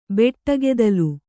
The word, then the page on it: ಬೆಟ್ಟಗೆದಲು: kannada